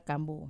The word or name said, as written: kambu